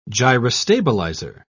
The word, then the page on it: gyrostabilizer: British English